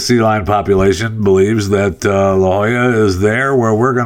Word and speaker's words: La Jolla: Is it La Jolla? Is that how you pronounce that? I know it's La Jolla.